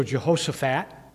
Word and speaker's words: Jehoshaphat: So Jehoshaphat, the PH is pronounced as an F.